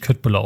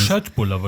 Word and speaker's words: Köttbullar: Schöttbulla wird es ausgesprochen.